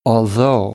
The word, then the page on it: although: American English